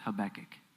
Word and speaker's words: Habakkuk: I'm going to pronounce it as Habakkuk.